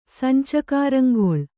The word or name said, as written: ಸಂಚಕರಂಗೊಳ್